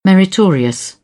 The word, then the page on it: meritorious: British English